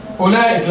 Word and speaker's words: أولئك: أولئك لما تيجي تكتبها فيها واو صح؟ لكنها لا تنطق. انت تقول اولى اولى اولى اولى اولئك فيها واو لكنها صفر مستدير لا ينطق.